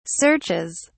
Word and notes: searches: British English

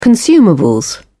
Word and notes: consumables: British English

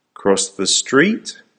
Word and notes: cross the street: American English

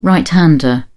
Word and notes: right-hander: British English

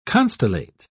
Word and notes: constellate: British English

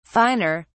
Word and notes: finer: British English

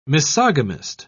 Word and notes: misogamist: British English

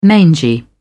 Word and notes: mangy: British English

